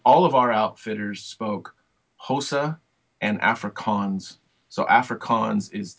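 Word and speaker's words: Xhosa: Hosa. So it sounds like Hosa, Hosa, but I think it's spelled Xhosha. I think I'm probably sure I'm saying that wrong, but it's Hosa is the language.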